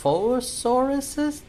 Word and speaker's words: Dilophosaurus: Um, some Spit Boys, these Diloph Dilophosauruses, Dilophosaurus? There we go. Um, launched some attacks at the ducks who were in their drinking hole. – Dilophosaurus.